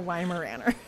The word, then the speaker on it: Weimaraner: A Weimaraner. Is that how we say it? – Yeah.